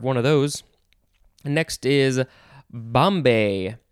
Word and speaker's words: bombé: Bomb or bombé.